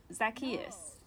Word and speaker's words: Zacchaeus: Zacchaeus. Z-A-C-C-H-A-E-U-S.